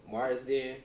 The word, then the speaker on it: Marsden: So, uh, I saw on Collider that uh, they had this like best characters voiced by Jason Mart. I think I'm saying his name wrong. Marsden.